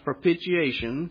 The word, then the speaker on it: propitiation: Your Bible said, whom God has set forth to be this word right here, it's pronounced propitiation, through faith in his blood, to declare his righteousness for the remission of sins that are past through the forbearance of God.